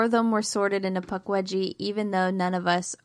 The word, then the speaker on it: Pukwudgie: I was forced first sorted into Pukwudgie and then Thunderbird, I think, might have been Horned Serpent. And I see myself as a Pukwudgie.